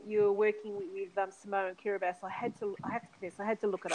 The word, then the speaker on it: Kiribati: K-I-R-I-B-A-T-I, but it's pronounced Kiribati.